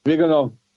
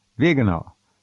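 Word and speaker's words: Viganò: Why hasn't Bishop Vigano, I may be pronouncing that wrong, is it Vigano? – Vigano. – Vigano.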